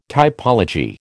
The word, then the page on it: typology: American English